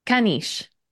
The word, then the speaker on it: caniche: Caniche.